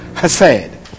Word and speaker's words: Hesed: It comes from a Hebrew word, Hesed. that's where they pronounce Hesed. H-E-S-E-D.